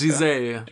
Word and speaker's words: Giselle: Äh, Gazelle? – Ja, ja, genau. – Nein, danke. Giselle. Giselle, genau. Giselle. Ich bin kein guter Franzose.